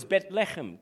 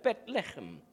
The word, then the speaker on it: Bethlehem: As Jules comes to the keys right now, the other last thing I wanted to make is Bethlehem, pronounced in Hebrew as Bethlehem. Can you say that? Bethlehem.